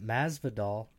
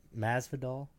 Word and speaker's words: Masvidal: Masvidal. – Mm-hmm. I hope I'm saying it right. – Yeah, Masvidal. I think Masvidal.